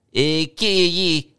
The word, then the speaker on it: iQIYI: odd name that I can probably never pronounce. I-Q-I-Y-I. Icky.